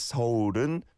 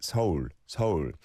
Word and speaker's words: 서울: And so it's not one syllable like in English, it is 서울. But when you say it together, it does almost sound like one syllable, even by Korean speakers, right? 서울, 서울.